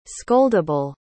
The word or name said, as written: scoldable